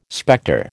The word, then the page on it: spectre: American English